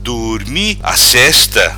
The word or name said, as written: Durmi Assesta